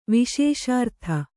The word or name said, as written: ವಿಶೇಷಾರ್ಥ